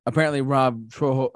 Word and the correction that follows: Trujillo: actually his last name Trollo, the bassist, forget how to say his name, Robert, I think it's Trujillo, isn't it? – Trujillo. – Yeah, Trujillo, yeah.